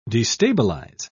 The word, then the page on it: destabilise: British English